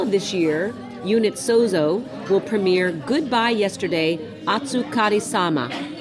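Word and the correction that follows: otsukare sama (お疲れ様): How did I do with that word? – Okay. I forgot to give you pronunciation. – No, I'm okay. I feel good about it. I want to encourage people who don't know how to pronounce something to give it a shot and then ask for help. – Absolutely. – So give me help. – It's otsukare sama.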